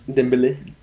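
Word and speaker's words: Dembélé: Messi passes the ball to Dembele. – I was pronouncing it. So Dembele gets it.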